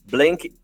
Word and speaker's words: Blekinge: mestre em liderança estratégica para a sustentabilidade pela Blank Institute of Technology. Ah, falei certo? Tá certo.